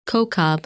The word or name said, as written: Kochab